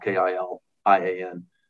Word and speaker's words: Kilian: My last name is Kilian. It's 1L, Kilian.